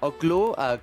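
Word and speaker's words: Oklou: Oklu,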